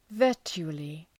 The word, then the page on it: virtually: ‘vɜ:rtʃu:əlı